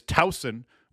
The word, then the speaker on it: Towson: I believe the proper pronunciation is Towson, with a soft S, not that hard Z-sounding S.